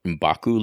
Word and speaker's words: M'Baku: You get the sense that the water supply, the fish comes from the mountain where M'Baku lives and stuff like that.